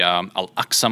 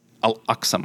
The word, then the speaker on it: Al-Aqsa: It has the Al-Aqsa Mosque. Did I say that right? – So, yes, you did.